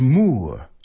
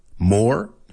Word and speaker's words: moor: Now, you guys all went with the British pronunciation, because in British, the word is moor, moor, moor. But in American English, the moors, more, hear any difference there?